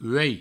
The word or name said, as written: uei